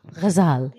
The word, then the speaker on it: Ghazal: Ghazal.